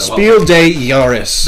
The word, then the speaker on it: Spiel des Jahres: Spiel des Jahres.